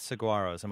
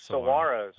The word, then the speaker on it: Saguaros: And our final of the six teams in the AFL, the surprise Saguaros. Am I even saying that right? – Yes, Saguaros. Right. No, it's Saguaros.